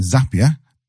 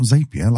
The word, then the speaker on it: Zapier: I found out recently Zapier rhymes with happier. So it's not Zapier like I was calling it.